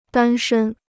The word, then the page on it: 单身: dān shēn